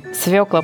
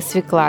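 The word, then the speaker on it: свёкла: А вот довольно частая ошибка в ударении в слове «свекла» возникла от того, что в письме буква «ё» часто теряется. А так как «ё» в слове всегда ударная, то с ее потери теряется и ударение.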